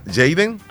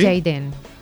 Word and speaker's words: Jaden: Se escribe Jaden. Ariel García. ¿Sería Jaden, Leslie? – Sí, ¿Sí? Jaden.